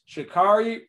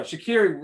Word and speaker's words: Sha'Carri: And then you also have, please excuse me if I say it wrong, because I've seen it pronounced different ways. It's Sha'Carri Richardson. I'm sorry, Sha'Carri Richardson. Thank you, Quincy.